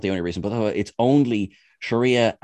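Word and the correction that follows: Shohreh Aghdashloo: and, I think I've pronounced that correctly, I think it's Shreya. Oh, I really don't want to get this wrong because she has such a wonderful name. And I had to learn it for one of the Trek Culture videos. I'm gonna do this right. She deserves to have her name pronounced correctly. There we go. Shorey Agadashlu.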